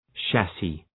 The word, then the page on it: chassis: ‘ʃæsız